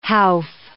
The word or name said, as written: howf